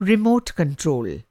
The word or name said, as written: रीमोट कंट्रोल